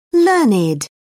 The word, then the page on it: learned: British English